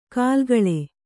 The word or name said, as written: ಕಲ್ಲಿಗೂಳು